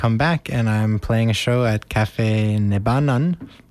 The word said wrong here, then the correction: Nebenan: I don't know how to pronounce it. – Nebenan? – Nebenan, thank you. It's my German.